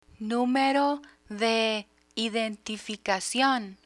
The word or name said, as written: numero de identificacion